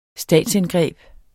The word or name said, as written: statsindgreb